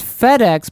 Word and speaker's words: Fede: Since Isla asked this last time, my name is pronounced the same as FedEx, but without the X. So Fede. Yeah, German.